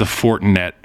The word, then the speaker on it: Fortinet: He won the Fortnite Championship. I know that's not how you say it. – Did he really? – It's the Fortinet Championship.